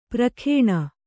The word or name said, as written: ಪ್ರಖೇಣ